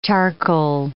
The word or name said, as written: charcoal